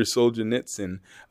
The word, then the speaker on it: Solzhenitsyn: It's Alexander Solzhenitsyn.